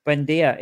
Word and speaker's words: Buendia: Buendia.